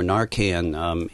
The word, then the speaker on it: Narcan: Narcan, drug. – Narcan, naloxone.